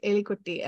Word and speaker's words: Elikutty: But so a lot of people think I'm pronouncing my name wrong and they're like, no, no, no, you should say Elikutty. I'm like, no, it's a little joke, Elikutty.